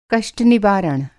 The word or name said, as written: कष्ट-निवारण